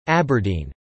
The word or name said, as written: Aberdeen